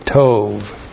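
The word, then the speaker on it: tov (טוֹב): T, long O, B, tov.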